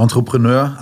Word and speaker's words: Entrepreneur: ein Entrepreneur zu sein. Ich weiß nicht, ob ich das richtig ausgesprochen habe. Das ist ein französisches Wort. – Genau, Entrepreneur, Entrepreneurship kommt aus dem Französischen.